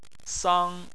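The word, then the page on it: sang: tone 1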